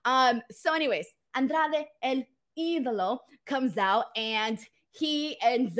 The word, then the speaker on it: Andrade El Idolo: FYI, for those of you guys who are confused on the pronunciation, uh, Andrade El Idolo. It's not that hard.